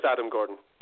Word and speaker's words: Gordon: Well, the W silent as well, so it's just Adam Gordon.